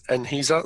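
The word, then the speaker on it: Enhesa: Yeah, it is Enisa. That is the official pronunciation of the company name.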